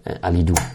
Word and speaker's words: Alidu: Wie wird Alidu ausgesprochen? – Alidu. Ist ja so einfach.